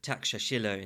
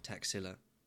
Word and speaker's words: Taxila: Taxila is actually pronounced Takshashila in Sanskrit, but I'm going to go with the modern pronunciation, Taxila.